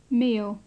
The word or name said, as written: meal